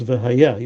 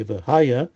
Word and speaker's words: V'haya: Okay, and that we pronounce V'haya. You wouldn't say V'haya, V'haya.